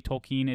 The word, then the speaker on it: Tolkien: So when we hear people say Tolkien, it sounds bad, and we all get annoyed by it. But guys, that is the proper way to pronounce it, so don't freak out.